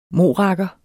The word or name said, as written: MORAKKERES